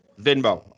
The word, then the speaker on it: Venmo: Venmo.